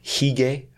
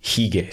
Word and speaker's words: hygge: Are you familiar with the Danish term hygge? I think it's spelled H-Y-G-G-E. – Hygge. – Or maybe hygge.